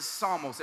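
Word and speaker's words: psalmos (ψαλμός): The Greek is pronounced psalmos, but in the Hebrew, it's pronounced mizmor, which means song.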